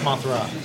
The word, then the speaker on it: Mothra: And Godzilla fought Mothman. I saw that movie. – I think it's Mothra. – That's how you say it.